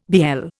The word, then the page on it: BL: Indian